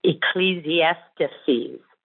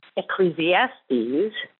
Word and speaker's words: Ecclesiastes: You said it was pronounced Ecclesiastes, which is a six syllable word that you might be confusing with Ecclesiasticus.